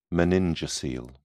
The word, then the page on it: meningocele: British English